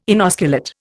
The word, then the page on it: inosculate: Indian